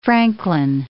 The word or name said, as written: Franklin